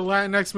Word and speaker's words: Latinx: Do you say Latinx?